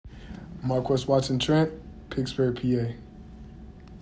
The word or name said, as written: Marques Watson-Trent